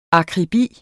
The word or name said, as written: akribi